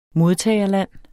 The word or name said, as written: modtagerland